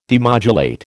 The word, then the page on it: demodulate: American English